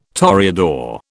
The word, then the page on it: toreador: British English